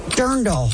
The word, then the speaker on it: Dirndl: I said it wrong the other day. I called it a dreidel. – not a dreidel. – It's drindle. No, Dirndl. – Dirndl. Dirndl is the correct answer.